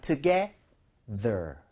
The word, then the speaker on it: together: 이것도 이왕이면 together, T-H-E-R, T-H가 살아있죠? – 네. – 그러니까 윗니 아랫니 사이에 혀를 살짝 올려놓는 느낌으로 제가 두 번 발음 들려드릴게요. 약간 이런 느낌. together까지는 좋은데요. together, 이런 느낌입니다. 해볼게요.